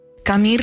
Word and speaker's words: Camus: Narrado por nuestros compañeros de Un libro, una hora, inaugura la primera novela del escritor argelino-francés Albert Camus, o Camus, como se pronuncia en su idioma natal.